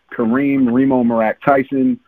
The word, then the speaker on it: Kareem Reem'o Meerak Tyson: This is Kareem Reem'o Meerak Tyson out of home, New York.